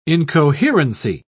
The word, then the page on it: incoherency: British English